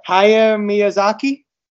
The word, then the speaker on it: Hayao Miyazaki: Hayao Miyazaki. Did I get that close? There you go. We're going to be talking about Hayao Miyazaki film, Howl's Moving Castle.